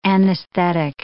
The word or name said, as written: anesthetic